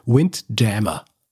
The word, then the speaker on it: Windjammer: Lustigerweise haben sie nichts mit dem Jammer zu tun, sondern es ist einfach die deutsche Aussprache des ursprünglichen englischen Begriffs Windjammer.